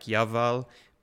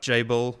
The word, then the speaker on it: Jabal: I think his name pronounced in Hebrew is more like Yaval, but Jabal, we'll go with that for our English-speaking audience.